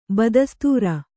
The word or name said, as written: ಬದಸ್ತೂರ